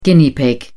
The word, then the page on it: guinea pig: British English